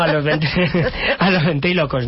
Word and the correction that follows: ventrílocuos: No, ventrílocuos, ¿no? Ventrílocuos. Sí.